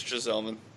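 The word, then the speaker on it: Gsellman: You're correct. It's Gieselman.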